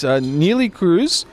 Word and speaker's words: Neelie Kruse: It's Neelie Kruse.